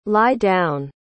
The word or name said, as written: lie-down